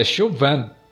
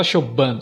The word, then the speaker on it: Chauvin: mas a, a, pronúncia é Chuban, Né? O B você não fala, você não fala Chuban, você fala Chuban.